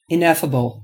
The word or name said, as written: ineffable